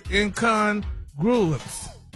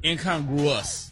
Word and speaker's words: incongruous: So this week's word is incongruence. Incongruence? – Wow. – Wait a minute. – Incongruence. – Okay. What? That's not it? – It was closer the first time. – Incongruous. – That was supposed to be the word. – You messed it up. I knew I was saying it right. – The writer messed it up. – They messed it up, Ricky. – It's incongruous.